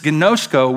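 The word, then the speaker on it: ginosko (γινώσκω): You can see the G-I-N-O-S-K-O, that's pronounced ginosko. Ginosko.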